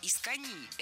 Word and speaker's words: искони: Первое – «искони». Это наречие имеет ударение на последнем слоге и пишется с одной «н» – «искони».